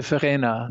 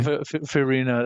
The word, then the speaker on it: Verena: Well, she's originally from Austria. You would say Verena. – Verena. – We can say Verena. That's fine.